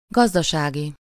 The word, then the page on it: agricultural: [ˌæ.ɡrɪ.ˈkəl.tʃə.rəl]; /ˌæɡɹɪˈkəltjʊəɹəl/; /ˌæɡɹɪˈkəlt͡ʃəɹəl/; /ˌæ.ɡrɪ.ˈkəl.tʃrəl/